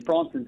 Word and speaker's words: Bronson: Yeah, my name is Bronson, so it rhymes with Don. It's pretty close.